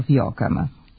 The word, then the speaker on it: fioka: Prema tome, fioka koju smo preuzeli od mađarske reči fiok nema glas J, mada se njegovi elementi mogu čuti u izgovoru.